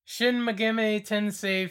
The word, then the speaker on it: Shin Megami Tensei: Shin Megami Tensei 5? God, I thought I was going to butcher that, but I think I nailed it.